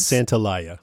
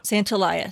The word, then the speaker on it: Santaolalla: I think it's Santaolalla. – Santaolalla. Santaolalla. Okay, yeah. Then we're going to go with that.